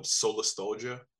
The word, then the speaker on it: Solastalgia: The band project is called Solastalgia, kind of like nostalgia, like you're missing the sun, like your sad boy.